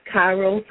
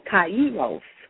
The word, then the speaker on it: Kairos: So again, we thank you for joining us, and our topic this morning is right time, right place, a Kairos moment, and that can be pronounced Kairos or Kairos in Jesus' name.